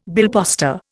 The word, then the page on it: billposter: Indian